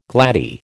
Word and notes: gladdie: American English

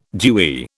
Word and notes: dewy: British English

